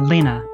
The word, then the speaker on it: Lena: Is it Lena or Lena? – Lena.